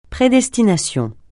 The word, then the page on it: prédestination: francais